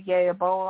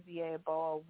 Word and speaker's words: Yeboah: You could also find me by name, Maya Akia Yeaboa, on Facebook. – It's spelled M-A-A-T. My middle name is Akia, A-K-E-E-A. And last name is Yeaboah, Y-E-B-O-A-H.